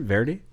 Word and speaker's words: Verity: It is Verity. Is that how you pronounce it? Verity? – Sure.